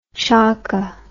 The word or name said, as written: chokka